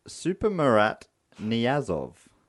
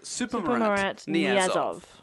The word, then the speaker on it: Saparmurat Niyazov: It's Saparmurat Niyazov. – Okay, so his name is Saparmurat Niyazov. – Yes.